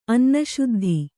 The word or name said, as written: ಅನ್ನಶುದ್ಧಿ